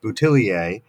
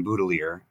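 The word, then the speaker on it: Boutilier: And for those who are wondering about my pronunciation, because it looks like it ought to be pronounced Boutillier, Clive Boudelier was from the Canadian province of Nova Scotia, which has a large population of people who are of French descent, but who have long since abandoned French language. And so when I corresponded with the family, they let me know that they pronounced the family name Boutelier, and so I follow suit.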